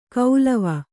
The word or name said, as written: ಕೌಲವ